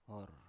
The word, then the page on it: or: Biak